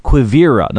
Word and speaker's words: Quivira: Now, that's what they called the land that Cabeza de Vaca had heard about, as well as the mysterious land called Quivira. Now, let me spell that in case I mispronounced it. Q-U-I-V-I-R-A.